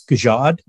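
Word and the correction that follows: jugaad: Is that it? This Jugad. – Jugad.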